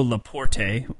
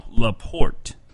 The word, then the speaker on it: Laporte: First, it's not Leo Laporte. Uh, several people have informed me that I kept mispronouncing it, and I apologize for that. It's Leo Laporte.